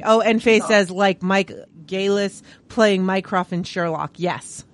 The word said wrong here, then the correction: Gatiss: Oh, Mark Gatiss, yeah. – Yeah. Oh, is that how I say it? I can't pronounce things. – It's a T.